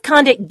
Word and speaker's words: Condit: Condi in French, C-O-N-D-I-T, means something sugary sweet used to mask a bad-tasting medicine. In English, it's pronounced condit, as in Gary,